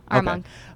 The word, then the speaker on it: Armonk: So, I mean, you mentioned, so is it Armonk? Is that how you say it? – Yeah, Armonk.